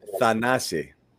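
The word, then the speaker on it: Thanasis: Let's give everybody the correct pronunciation on it. Thanasi.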